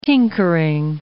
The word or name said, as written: tinkering